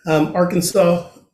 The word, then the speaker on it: Arkansas: Yes, let's keep doing our little trickling in Hawaii and Fort Lauderdale and in Arkansas.